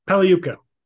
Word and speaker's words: Pagliuca: Steve Pagliuca, how do you say it? – Pagliuca. – Pagliuca. God, it's like I don't even have a tongue.